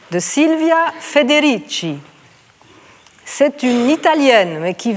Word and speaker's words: Federici: « Caliban et la sorcière » Silvia, avec des « i », Federici, comme ça se prononce.